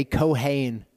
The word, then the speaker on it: kohen: So priest in Hebrew is kohen. Okay, kohen is how you pronounce that.